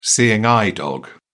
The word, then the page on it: seeing eye dog: British English